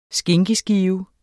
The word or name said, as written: skinkeskive